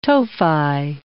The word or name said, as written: tophi